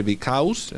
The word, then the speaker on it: because: Because es más con, tirando a una O, más que decir because, because. Aunque se escribe because, bueno, vamos a decir because. – Because. – Y cuanto más corto mejor, because.